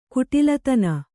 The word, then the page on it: ಕುಟಿಲತನ: kuṭilatana